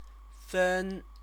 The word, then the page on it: fen: chinese